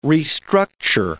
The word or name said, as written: restructure